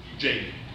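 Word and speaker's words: Tjaden: And so I assume that what you pointed us to is the same thing that Dr. Tijin. – Jaden. There's the T is silent.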